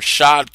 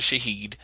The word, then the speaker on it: Shahid: Shahid Khan, or Shad Khan, some people call him, but based on what I looked and the pronunciation, it's actually Shahid.